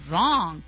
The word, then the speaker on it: wrong: And I'm always incorrect, it means that, and I start silently because you don't pronounce the W in wrong, right? We start silently, but the rest of me can easily be heard.